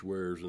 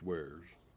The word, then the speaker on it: weirs: The Rolling Fork River would have been full of fish wares in those days. And if you want to look that up, that's spelled W-E-I-R-S, fish wares.